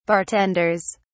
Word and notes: bartenders: British English

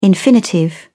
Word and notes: infinitive: British English

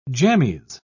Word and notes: jammies: British English

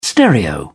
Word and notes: stereo: British English

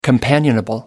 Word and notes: companionable: American English

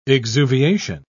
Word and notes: exuviation: British English

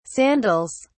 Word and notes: sandals: British English

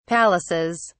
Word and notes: palaces: British English

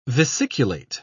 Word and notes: vesiculate: British English

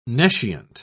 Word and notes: nescient: British English

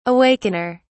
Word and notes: awakener: British English

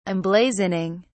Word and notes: emblazoning: British English

